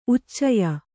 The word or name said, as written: ಉಚ್ಚಯ